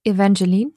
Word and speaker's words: Evangeline: Evangeline.